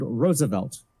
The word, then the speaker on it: Roosevelt: As for name, it is pronounced as if it was spelled Roosevelt. It has three syllables, and the first syllable as if it was Rose.